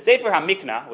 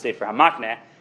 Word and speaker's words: Sefer HaMikna: Now, the Sefer HaMikna, which is most commonly mispronounced as Sefer HaMakneh, has a very fascinating suggestion where we finally get to our Zimun case.